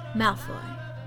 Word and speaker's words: Malfoy: Uh, no, you said it wrong. Malfoy, Draco Malfoy.